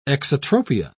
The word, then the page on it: exotropia: British English